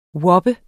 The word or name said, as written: wappe